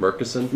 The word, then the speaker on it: Murchison: Murchison? – Yeah, you said it right.